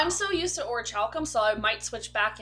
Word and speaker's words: orichalcum: Because I found out that's not actually how you pronounce it. – It's supposed to be orichalcum. – Yeah. – What the bullshit? – Yeah. – Yep, I looked it up online after they mentioned it in Dragon Quest, and it is orichalcum. – Because it is an actual Greek word.